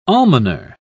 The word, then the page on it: almoner: British English